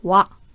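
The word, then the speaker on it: Wa (は): Nur wenn man zu diesem Satz-Tierpartikel zurückgeht, dann spricht man das Ganze wa aus.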